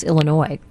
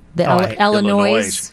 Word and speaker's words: Illinois: People even mispronounce Illinois. Illinois. – Yeah, Illinois. Yes. – Yeah. – My roommate in college did that. And I was like, wait a minute. You're Northwestern. You got to say it right. – Well, there's Illinois and Illinois. I happen to bristle when I hear Illinois, but I know there's a lot of people who say it.